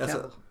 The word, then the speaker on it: Cavill: Yeah, I say Cavill. – That's how it's pronounced.